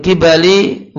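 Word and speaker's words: qibal: Maka iblis datang, min kibali wajihihi, itu bacanya kibal, dari arah wajahnya Ayub.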